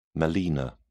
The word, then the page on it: melaena: British English